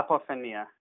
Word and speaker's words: apophenia: Correct me if I'm pronouncing it right, or if I'm not pronouncing it right. Apophenia? – Yeah, apophenia.